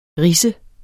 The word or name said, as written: ridse